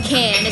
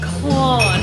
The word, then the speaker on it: Cannes: It's actually pronounced Cannes, as in a can of beer. I had to Google this because someone, I won't say who, kept saying con, and it's really douchebag.